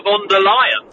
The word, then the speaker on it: von der Leyen: Okay, well, first of all, just on the pronunciation, it's Ursula von der Leyen.